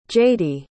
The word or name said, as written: jadey